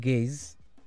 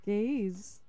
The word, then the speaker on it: Gaize: They're called Gaze. – Gaze. – They spell it GAIZE.